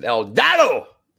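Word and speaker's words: El Idolo: Idalo, that's how you pronounce it. El Idalo.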